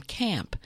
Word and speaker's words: Caamp: And in between we heard from Camp, and that's spelled C-A-A-M-P.